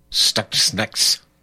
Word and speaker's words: Stuxnet: How do you pronounce that, Mikey? Stuxnet.